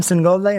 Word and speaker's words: Singolda: Adam Singola, you know, I thought it was good.